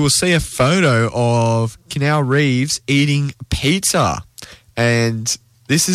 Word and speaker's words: Keanu: That's not it. It's Keanu.